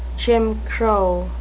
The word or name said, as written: Jim Crow